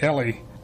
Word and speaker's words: Eli: Eli, or Eli. The Jews today pronounce it Eli.